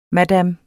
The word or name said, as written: madame